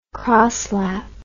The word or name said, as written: crosslap